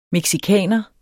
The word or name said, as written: mexicaner